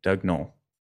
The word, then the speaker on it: Doug Noll: And so I am excited to have with me today, Doug Noll.